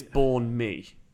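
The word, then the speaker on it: bánh mì: Banh mi is how I think most people I've heard say it. But apparently it's born mi. – Yeah. – That's how you pronounce that.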